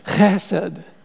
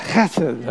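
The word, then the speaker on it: chesed (חסד): In fact, if you want to say it properly, you've got to put like a CH on the front. You've got to go chesed. Try that, chesed, okay?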